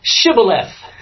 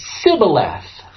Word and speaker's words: Shibboleth: Then they said to him, well, say now, Shibboleth. And he would say, Sibboleth, for he could not frame to pronounce it right.